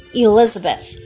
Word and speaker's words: Elizabeth: My name, again, is Elizabeth, and means El is my oath.